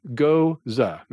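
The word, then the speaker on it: Gose: So let's talk about the Goza, and it is pronounced Goza. That's not me writing it. That's what the real pronunciation, spelt goes like a hose with a G, but pronounced Goza.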